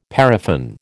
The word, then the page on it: paraffin: American English